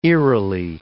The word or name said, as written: eerily